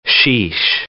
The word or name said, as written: sheesh